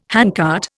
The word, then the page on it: handcart: Indian